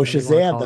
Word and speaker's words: Shazam: By they put out that Sajam. I can't pronounce it. Sajam. – Oh, Shazam, the Fury of the Gods.